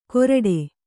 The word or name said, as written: ಗೋರಟೆ